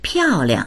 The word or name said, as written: piao4liang